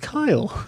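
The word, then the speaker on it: Keil: Yeah, and it's K-E-I-L. – Yes. So for those of you listening. – that was fun. Yeah, that was pretty funny. It's like, I learned so much from these podcasts. Like, it's pronounced Kyle.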